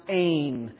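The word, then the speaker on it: ain (ἦν): Okay, and then this one here is pronounced ain. It has that eta. And eta ate your lunch. Eta will eat your lunch, okay? Because I've always had a hard time with that. So eta ate your lunch. That's a hard letter. So that would be Ain.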